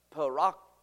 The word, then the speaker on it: paraklesis: Here it's paraklesis is the pronunciation.